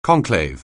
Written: conclave

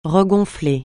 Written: regonfler

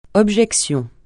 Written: objection

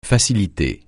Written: faciliter

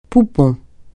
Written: poupon

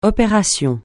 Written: opération

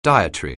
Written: dietary

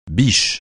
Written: biche

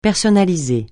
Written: personnaliser

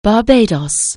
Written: Barbados

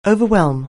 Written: overwhelm

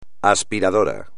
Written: aspiradora

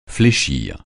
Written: fléchir

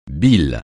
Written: bile

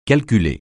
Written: calculer

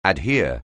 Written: adhere

